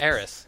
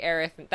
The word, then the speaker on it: Aerith: Was Aeris. – Yeah. – So her name is going to be Aerith. That was originally how it was supposed to be. – Pronounced. Correct. – And that's how you pronounce.